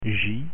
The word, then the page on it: j: French